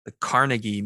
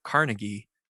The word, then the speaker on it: Carnegie: I about a month ago, I found myself in the Carnegie Museum in Pittsburgh. Or here's a good trivia for your listeners. In Pittsburgh, they don't pronounce it Carnegie. They pronounce it Carnegie. And that threw me off, I'll tell you what.